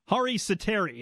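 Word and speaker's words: Harri Säteri: All right, let me get the pronunciation here straight first. Capitals tonight, they're going to see the Panthers' third-string goalie, Hari Sateri, yes? – You got it.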